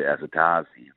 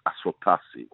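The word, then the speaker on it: Asotasi: Like, you know, they used to call him Roy Azatazi, for example. You know, it's Aswatasi, you know.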